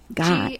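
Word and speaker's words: Gies: Guys. – G-I-E-S. G-I-E-S. Pronounced Guys, and Micah Wong? – Yeah.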